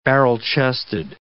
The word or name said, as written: barrel-chested